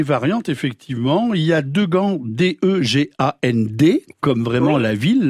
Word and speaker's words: Degent: On trouve également Degand, D-E-G-A-N-D-T, Degand, D-E-G-A-N-T, et Degand, qui se prononce Degand, mais en fait qui s'écrit D-E-G-E-N-T.